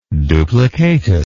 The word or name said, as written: duplicatus